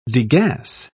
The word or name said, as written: degas